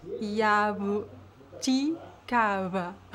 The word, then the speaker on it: jabuticaba: É um B onde você não encosta os lábios. Você só fricciona eles de leve. Então fica. Então, yabuticaba. Yabuti. Yabuti kava. Kava. – Yabu. Eu tô pronunciando bem? Yabu. Calma. Vou tentar de novo. Yabuti kava. – Isso, tá muito bom, parabéns.